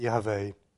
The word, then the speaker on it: Yahweh: the Hebrew form should be transliterated as Yahweh, or my Israeli friends, if they would pronounce it, the very special name, would actually pronounce it Yahweh.